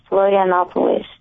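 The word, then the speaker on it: Florianópolis: Is that how you pronounce the name? – Yes, Florianopolis.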